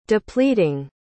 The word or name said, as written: depleting